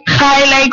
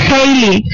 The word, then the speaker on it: Hailey: Em, nos toca pues cambiar de tema y hablando pues de lo que os decía, eh, no es Hailey como yo lo pronunciaba, que yo lo pronunciaba muy la inglesa, sino que es Hailey, Hailey Hailey. ¿eh? O sea, ellos que mm, ya lo veis que, en fin, Hailey eh, no es como el cometa, es como Hailey, eh, eh hola, hola clase.